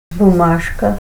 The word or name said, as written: bumashka